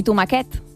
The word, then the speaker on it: tomaquet: Que és com tomàquet i tomaquet. El diminutiu de tomàquet. – És veritat. – S'escriu igual que tomàquet, però es pronuncia diferent.